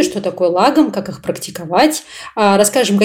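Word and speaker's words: lagom: И вот лагом более сложное для меня слово, потому что я всегда забываю, куда там правильно ставить ударение. И я специально перед подкастом погуглила, мне интернет сказал мне все-таки лагом на первый слог.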